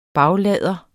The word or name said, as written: baglader